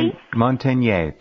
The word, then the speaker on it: Luc Montagnier: Luc Montagnier.